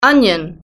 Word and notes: onion: British English

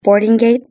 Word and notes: boarding gate: British English

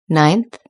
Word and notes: ninth: British English

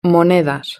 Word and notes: monedas: Spain Spanish

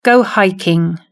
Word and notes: go hiking: British English

